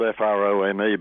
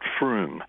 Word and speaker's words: Frome: It's spelled F R O M E, but the locals pronounce it Froome.